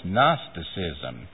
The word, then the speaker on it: Gnosticism: Gnosticism. You've seen that word, I'm sure. It is spelled with a G.